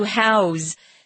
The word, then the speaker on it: house: If it's a noun, it's an S sound, house. But if it's being used as a verb, then it's a Z sound, to house.